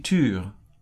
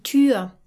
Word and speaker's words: Tür: Let's emphasize again the pronunciation of die Tür. This is a tricky pronunciation for English speakers with that very narrow ü sound. Can we hear that again? – Die Tür.